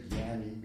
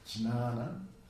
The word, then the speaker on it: jnana: In Sanskrit, there's two words, jnana, really, if you pronounce it correctly, we say jnani because we're speaking like Bengalis or Hindis, but it's jnana.